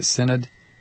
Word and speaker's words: Synod: Synod? – Yes.